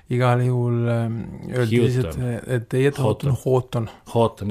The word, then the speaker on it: Houghton: Head raadiokuulajad, ei tule siia Israel Houghton, vaid tuleb hoopis Israel Houghton, aga laulud põhimõtteliselt on samad.